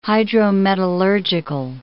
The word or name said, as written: hydrometallurgical